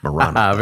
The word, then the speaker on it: Maranatha: Then she signs her note, Maranatha, pronounced correctly, of course, she says, Maranatha.